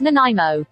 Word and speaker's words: Nanaimo: It's pronounced Nanaimo.